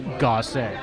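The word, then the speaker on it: Gose: Okay, so the bartender first said, well, the correct pronunciation is gosse.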